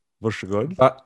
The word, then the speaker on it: Varsågod: Varsågod.